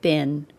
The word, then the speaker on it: been: However, Americans say bin with a short I, as if the word was spelled B I N.